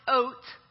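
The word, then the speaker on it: oat (אות): The first time that the Hebrew word for sign, which is pronounced oat, the first time that the Hebrew word for sign is used is in the very first chapter of Genesis.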